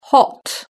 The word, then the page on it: hot: British English